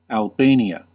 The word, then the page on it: Albania: American English